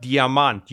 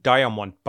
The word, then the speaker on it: Diamant: That company called Diamant. You would pronounce it Diamond Bikes.